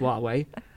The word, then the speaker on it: Huawei: But basically, I go into, you know, Huawei, which a lot of people pronounce Huawei, but it's just Huawei.